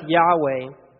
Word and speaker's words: Yahweh: But YHWH, which we pronounce Yahweh, we don't actually know how that name was pronounced because it was never spoken.